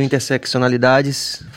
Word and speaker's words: Interseccionalidade: Então, as, suas, as duas publicações, o primeiro Interseccionalidades, falei certo? interseccionalidade. – Interseccionalidade. Mas pode chamar de encruzilhada.